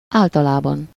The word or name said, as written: généralement